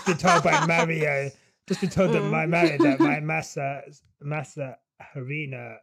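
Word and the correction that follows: masa harina: pronunciation was wrong. The H is silent. Massa Arena.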